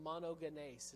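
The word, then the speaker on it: monogenes (μονογενής): It says monogenes is how you say that word.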